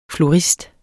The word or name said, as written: florist